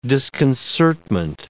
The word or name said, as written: disconcertment